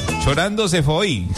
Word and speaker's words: Chorando se foi: Chorando se foi.